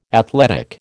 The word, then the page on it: athletic: American English